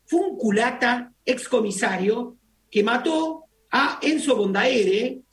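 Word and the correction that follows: Bordabehere: no sé si lo digo bien, que fue... – Bordavere, que fue...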